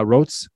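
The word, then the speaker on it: Roets: It's R-O-E-T-S. I'm not quite sure how to pronounce her last name. – Yeah, Rotes. Yes. Yeah, I believe that's how.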